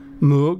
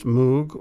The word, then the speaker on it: Moog: And actually, it can be pronounced Moog or Moog, which is how his family pronounced it. And he himself pronounced it.